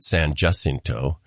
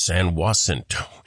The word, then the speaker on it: San Jacinto: And I found out that this one's kind of problematic, that you can pronounce it, if you use this classic Spanish pronunciation, it's San Jacinto, like you said. Mm-hmm. But the locals there pronounce it San Jacinto.